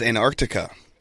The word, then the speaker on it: Antarctica: My hot seat is Antarctica.